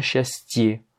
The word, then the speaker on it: счастье: Regarde, c'est le dernier paragraphe, le yé à la fin de счастье, la joie, il n'est pas accentué. Pourtant, il se prononce bien yé. J'ai pas dit счастье, j'ai dit счастье. Hein, c'est bien счастье. Parce que, comme il est en fin de mot, il va rester tel quel, il ne va pas se prononcer comme un i, la règle ne s'applique pas.